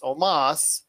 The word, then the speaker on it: Omos: Up next was AJ Styles and Omos, even though it's spelled almost. It's Omos, defeated Kofi Kingston and Xavier Woods to win the Raw Tag Team Championships.